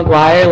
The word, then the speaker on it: Maguire: Good evening, my name is Mikhail LaGuardia, my last name is spelled M-A-G, and this is on... – Thank you. My name is Michaela McGuire, last name spelled M-A-G-U-I-R-E.